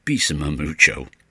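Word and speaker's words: Bésame Mucho: Klaus Wunderlich and Bessame Mucho, or if you prefer the vocal group pronunciation, Bessame Mucho.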